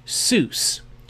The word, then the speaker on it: sus: The primary word translated here as delight is the Hebrew word sus. You pronounce it like Dr. Seuss.